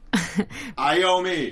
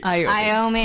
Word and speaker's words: Iommi: Tony Iommi. – Iommi.